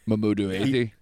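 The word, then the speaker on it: Mamoudou Athie: Mamoudou Athey.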